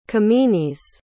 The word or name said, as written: kemenes